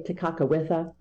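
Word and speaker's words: Tekakwitha: Now, I might not have pronounced that right. I did a show for her in 2021, and I gave the Mohawkian pronunciation of her name. Normally I say Tekakawitha, but that's kind of anglicized.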